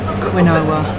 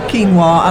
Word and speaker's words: quinoa: I know a lot of people call it quinoa. That's what it looks like. We actually pronounce it quinoa, and I'm with Christy, our producer here, and we've stopped off, and we're talking to Stephen Jones.